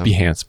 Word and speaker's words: Behance: It's pronounced Behance, by the way. Behance.